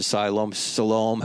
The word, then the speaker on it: Siloam: there's many different ways of pronouncing it. I pronounce it Siloam, however you want to pronounce it.